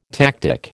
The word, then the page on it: tactic: American English